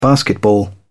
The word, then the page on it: basketball: British English